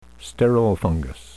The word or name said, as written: sterile fungus